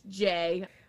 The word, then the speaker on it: Jeh: Jeh Johnson? J-E-H Johnson? – I don't know. – He was the Secretary of Homeland Security. – Hmm. Well. – Oh, it's pronounced Jay.